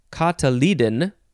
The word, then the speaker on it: cotyledon: Next word is a suffix, cotyl, or just cotyl, C-O-T-Y-L. And it just means cotyledon. No. It's pronounced cotyledon.